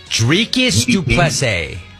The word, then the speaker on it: Dricus du Plessis: Dricus du Plessis.